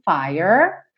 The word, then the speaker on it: fire: Então, the world was on fire, fire, paro no R, não sigo pro E, porque esse E é mudo. Então, ó, fi e dou o R lá enrolado, porta perna esquerda, fire, fire.